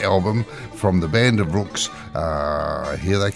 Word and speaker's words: Ruhks: We're going to open today's program with a fairly new recording from the Band of Rooks, and that's spelled R-U-H-K-S, but it's really pronounced R-O-O-K-S.